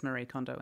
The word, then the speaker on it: Marie Kondo: And across the pond of the Pacific came this book by Mariko Kondo. I don't know how you say it, but she says Marie Kondo in English. – Okay, Marie Kondo.